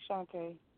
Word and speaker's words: Shante: Shante. It's Shante. – How you spell it? – Spelling. – S-H, I'm sorry. It's S-H-A-N-T-E.